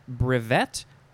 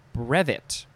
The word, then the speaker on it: brevet: Next is, you can emphasize either syllable. It is brevet or brevet. Brevet or brevet. B-R-E-V-E-T.